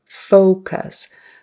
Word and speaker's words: focus: The first vowel is a long O, O, O, O, round your lips, focus. The last vowel, the U there, is a short U, cuss. Just don't do a short U in the first syllable as well. You don't want two short U's or you might say something you'll regret. So it's focus, focus. The word done, done, like I'm all done. Even though it's spelled D-O-N-E, it's got to be a short U.